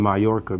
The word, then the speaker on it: Mallorca: It's written M-A-L-L-O-R-C-A, pronounced Mallorca.